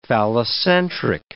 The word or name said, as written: phallocentric